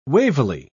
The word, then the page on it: wavily: British English